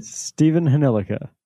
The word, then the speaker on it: Stephen Hnilica: Stephen Hnilica.